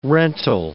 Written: rental

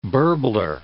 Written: burbler

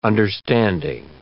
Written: understanding

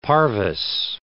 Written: parvis

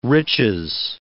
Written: riches

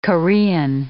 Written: Korean

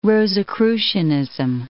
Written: Rosicrucianism

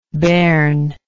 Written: bairnishness

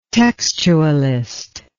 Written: textualist

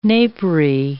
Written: napery

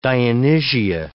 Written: Dionysia

